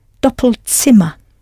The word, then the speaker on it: Doppelzimmer: You had to change the vowel sound slightly, double to doppel, and I hope you pronounced the Z as though it was a T-S, tsa, Doppelzimmer.